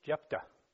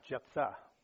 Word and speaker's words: Jephthah: So we're dealing with a man by the name of Jephthah, and I want to make sure I'm pronouncing that right, because my wife said there is no TH sound in the Jewish or Hebrew language. So it's Jephthah, okay, not Jephthah, which I was saying for many, many weeks. Jephthah the man,